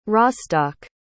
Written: Rostock

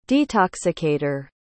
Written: detoxicator

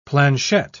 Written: planchette

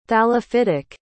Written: thallophytic

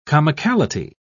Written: comicality

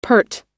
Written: peart

